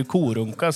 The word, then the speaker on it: Korunka: Uttalet är ju Korunka som Korunka. – Är bäst. – Ja. Korunka.